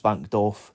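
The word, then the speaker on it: Wankdorf: I got that wrong many a time because it's actually a W. It's a silent W. It's Vankdorf. The Vankdorf Stadium, you can easily call it something else.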